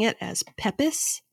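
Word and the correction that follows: Pepys: and it's actually pronounced Peeps.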